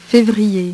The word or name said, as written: fevrier